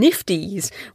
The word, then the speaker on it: NFTs: Also, hopefully you caught my in-between-isode on blockchain for copyright protection, smart contracts, digital scarcities, and nifties, which I have been told by several of you is how to pronounce NFTs.